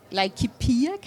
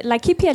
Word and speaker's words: Laikipia: om, nemlig jordkonflikter i øh, Kenya, i Kenyas Laikipia, Laikipia County. Er det rigtigt udtalt? Det er rigtigt.